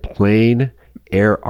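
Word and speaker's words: plein air: Plain Air Art Festival, according to our producers. Plain, spelled P-L-E-I-N, is outdoor painting.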